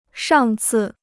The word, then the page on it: 上次: shàng cì